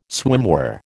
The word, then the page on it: swimwear: American English